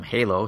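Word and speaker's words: José: I would prefer to call him Halo since you don't pronounce the J.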